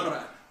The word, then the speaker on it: Segura: But his name is Spanish, I guess. – Is it? – Yeah, that's why he's Segura. Some shit like that. You got to say it like that. That's his real name. Segura.